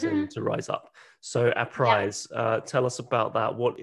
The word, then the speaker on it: APRISE: Yeah, APRISE, like apprising you of.